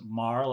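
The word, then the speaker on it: Mahar: I pronounce it Marr, like my cousins over in Ireland, from Tipperary, a small town called Templemore in County Tipperary, a farm named Anfield.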